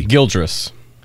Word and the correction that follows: Gilgeous: Shai Gildress Alexander. – There's no R in there. Shai Gilgeous Alexander.